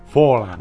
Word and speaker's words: Forlán: Forlán.